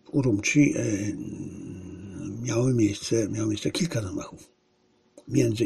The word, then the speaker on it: Urumqi: Z Urumqi, bo tak to się wymawia.